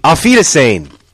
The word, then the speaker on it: Auf Wiedersehen: Came Auf wieder Schön. – What? Auf Wiedersehen. Auf Wiedersehen. That's how you say it.